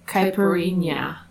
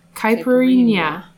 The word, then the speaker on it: Caipirinha: Caipirinha. – Got it? – Yeah. – Caipirinha.